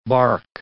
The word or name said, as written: barque